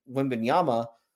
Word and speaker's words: Wembanyama: Wembanyama.